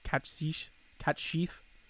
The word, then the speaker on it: Cait Sith: Are people going to be floored the first time they hear it pronounced properly? Catch sheath. – Yeah, it would. I usually just like saying Cait Sith.